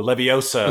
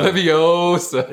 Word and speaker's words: Leviosa: I just thought about the Harry Potter spell that where they stress the O. – Oh, Leviosa. – Leviosa. – Yeah, not Leviosa.